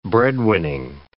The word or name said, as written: breadwinning